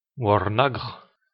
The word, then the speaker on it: Warnog: Warnog, qui se prononce en klingon Warnagr, c'est une sorte de bière.